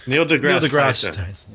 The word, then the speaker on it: Neil deGrasse Tyson: Neil deGrasse Tyson.